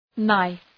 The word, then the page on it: knife: naıf